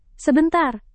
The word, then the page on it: sebentar: indonesian